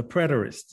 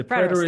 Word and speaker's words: preterist: But the preterist, I'm not even sure if I'm saying that right. – Yeah, well, the preterist. The preterist, thank you.